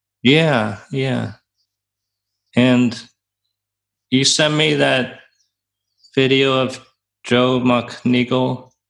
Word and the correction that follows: Joseph McMonagle: Is that how you say his name? – I think it's Joseph McMonagle.